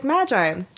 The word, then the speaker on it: Magi: And she was like, Magi.